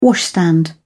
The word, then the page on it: washstand: British English